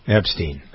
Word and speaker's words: Epstein: Jeffrey Epstein. Is it Steen or Stein? I don't know how to pronounce it. Epstein.